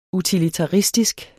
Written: utilitaristisk

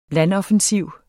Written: landoffensiv

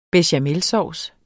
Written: bechamelsauce

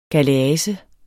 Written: galease

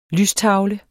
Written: lystavle

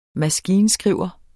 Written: maskinskriver

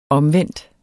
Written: omvendt

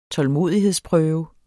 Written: tålmodighedsprøve

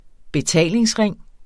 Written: betalingsring

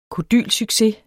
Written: kodylsucces

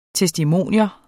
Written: TESTIMONIUMS

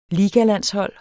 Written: ligalandshold

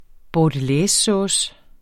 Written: bordelaisesauce

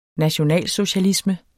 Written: nationalsocialisme

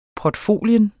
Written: portfolio

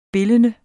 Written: bildende

